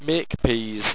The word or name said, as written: maquepees